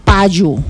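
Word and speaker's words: páyu: El pañuelo se dice payu y se escribe de esta manera. Empezamos con la letra P. – P. – A. – A. – Acento en la A, en este caso lleva un acento.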